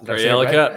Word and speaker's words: Karjala: So Matvey Michkov, it's funny, I've never said the name of this tournament out loud, the Karjala Cup. – Karjala Cup. – Right? Nailed it. – There are no hard J's in Europe, as I like to say.